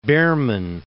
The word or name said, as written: Behrman